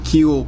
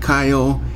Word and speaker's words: Kiel: And wide receiver Keel Pollard, not Kyle, K-I-E-L. Why it's pronounced Keel instead of Kyle, I don't know.